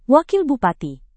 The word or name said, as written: wakil bupati